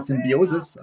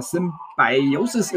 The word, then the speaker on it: Symbiosis: Da muss ich mich noch dran gewöhnen, dass das so ausgesprochen wird. Ich sage mal Symbiosis, aber Symbiosis ist richtig.